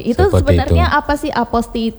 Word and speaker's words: apostille: Memang orang yang baru pertama kali melihat tulisan atau kata apostille ini pasti membacanya apostil ya, biasanya. Kalau mungkin ini serapan bahasa asing dan sebagainya. Nah, ini tapi sesungguhnya ini dari bahasa asing juga. Memang apostille ini dibacanya cukup aposti. – Aposti saja, nggak sampai tilenya. Aneh juga ya.